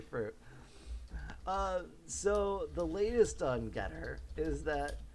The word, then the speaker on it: Gettr: Okay, so the website is called Getter, and that is spelled G-E-T-T-R, Getter.